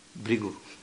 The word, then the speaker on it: Bhrigu: How do you pronounce actually? Bhrigu.